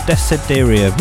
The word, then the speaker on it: Desiderium: It's called Soul in the Dark, and also played your Desiderium. Desiderium, yeah, that's right.